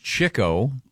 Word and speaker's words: Chico: It's pronounced Chico. Really? – Spelled Chico. – I've never heard that one. It's spelled Chico, but it's Chico.